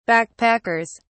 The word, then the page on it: backpackers: British English